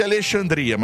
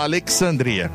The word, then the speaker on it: Alexandria: E encerrando, em Minnesota, nos Estados Unidos, tem uma cidade que eles pronunciam o nome como Alexandria. É como se fosse Alexandria, mas fala Alexandria.